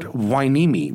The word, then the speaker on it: Hueneme: like their new one in Port Hueneme, which I am told is the correct pronunciation.